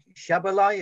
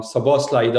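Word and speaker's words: Szoboszlai: Just finally, I wanted to ask you about your international teammate, Dominic Szabolaj. If I say his name right, I probably said that completely wrong. – Yeah, completely wrong. Szabolaj Dominic, yeah.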